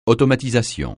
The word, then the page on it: automatisation: francais